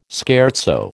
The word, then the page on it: scherzo: American English